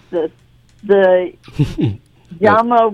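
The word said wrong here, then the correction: Ujamaa: workbook. – The UJAMA workbook.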